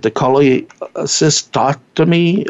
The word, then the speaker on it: cholecystectomy: And also, based on that minimal cut, they're also using, cholecystectomy? – Cholecystectomy.